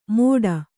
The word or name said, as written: ಮೋಡ